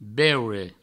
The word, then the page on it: beure: Provençal